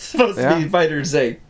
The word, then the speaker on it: FighterZ: That is how you pronounce it. It is pronounced FighterZ. – There's no space there. It's not FighterZ. It's FighterZ. – Yeah. – Yeah, well, that sounds ridiculous, so it's FighterZ. – No, it's officially FighterZ.